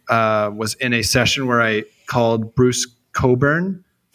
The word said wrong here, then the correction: Cockburn: and someone very graciously told me that it's pronounced Coburn.